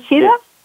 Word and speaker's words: Chadha: Chetan Chadha? – Chetan Chadha. It's a South Asian name, so sometimes it's hard. – Soft A. I have a long note there, so it's a soft A.